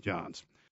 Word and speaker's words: Jahns: J A H N S, pronounced Johns.